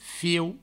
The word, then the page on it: fiu: fieu; Provençal